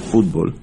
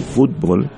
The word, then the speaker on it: fútbol: Eh, no el, los, americanos le llaman soccer, pero en el mundo entero es fútbol, con acento en la U, fútbol.